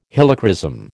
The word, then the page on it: Helichrysum: American English